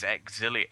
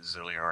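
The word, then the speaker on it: Xillia: yeah, I've, I've, even had access to the romanization of the Japanese title, and it is Exilia, uh, uh, Exilia but I just pronounce it Zilia anyway.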